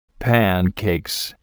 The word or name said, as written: pancakes